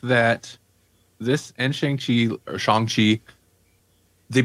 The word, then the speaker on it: Shang-Chi: we watched Shang-Chi. Thank you. I couldn't remember the correct pronunciation. Shang-Chi.